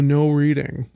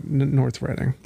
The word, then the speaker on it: Reading: And then it's labeled no reading, but North Reading. – Right. Yeah. I get it now. Okay. Yeah, because Reading is spelled like reading for some reason.